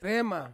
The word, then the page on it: rema: Ansus